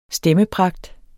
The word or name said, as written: stemmepragt